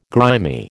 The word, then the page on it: grimy: American English